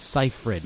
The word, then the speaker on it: Seyfried: This person I know, Amanda Seyfried. You guys know who she is, don't you? Oh, come on. Are you kidding me? – Seyfried. – Is it Seyfried? – Seyfried.